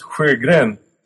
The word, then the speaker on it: Sjögren: The original was S-J-O with umlauts, Sjögren.